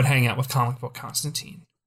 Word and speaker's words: Constantine: It's pronounced Constantine. Rhymes with turpentine.